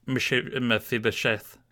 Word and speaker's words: Mephibosheth: He was gracious to Mephibosheth. Did I say that right? – I think you got it right the first time, yes.